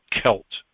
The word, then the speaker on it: Celt: understand that that word is actually pronounced Celt, because it comes from the Greek word Keltoi, which was the Greek's word for the Israelites when they traveled through Europe into the northern places and to the British Isles.